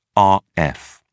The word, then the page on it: rf: British English